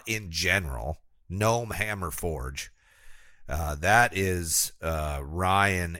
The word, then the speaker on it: Gnome: Gnome Hammer Forge. The G is silent.